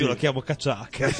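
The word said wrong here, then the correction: cachaça: Ecco, vedi, io avrei già sbagliato tutto chiamandola la cacciacca.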